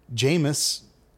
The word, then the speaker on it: Jameis: Jameis. Sounds like Jamie.